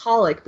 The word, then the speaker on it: Holic: Definitely, I think in Holic fandom, which is XXX Holic, but you pronounce it Holic, they, even in the, you know, English official translations of the manga, they do not translate this one word, which is hitsuzen.